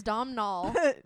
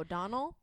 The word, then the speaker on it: Domhnall: Oh, I thought it was Domhnall. – No, that's just like an Irish way of spelling it. – Oh, Donnell.